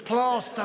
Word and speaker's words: Plaistow: Even though it's spelled Plaistow, it's Plasto.